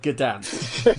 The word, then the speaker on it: Gdansk: That's how you pronounce the word Gdansk. The first word you pronounce as good.